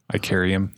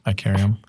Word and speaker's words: Icarium: Kind of like Ikarim. – Yeah. – Ikarim. – Icarium. That's how I pronounce him. – Icarium.